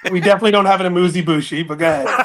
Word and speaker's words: amuse-bouche: So I would like the proper pronunciation of the amuse-bush. – The second one you're killing. So I'm not even 100% positive. It's either amuse or amuse, and then it's bouche. Amuse or amuse-bouche. Amuse-bouche.